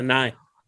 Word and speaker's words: Nighy: Nye. – I never know how to pronounce his name. – I think it's Nye. Yeah. – Okay. – Yeah, Nye.